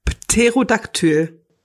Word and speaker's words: Pterodaktyl: Pterodactyl. – Das P ist da immer, ist stumm. – Pterodactyl, echt? Ein Pterodaktyl.